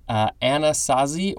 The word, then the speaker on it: Anasazi: Next we have, I hope I pronounced this correctly, Anasazi or Anasazi, capital A-N-A-S-A-Z-I.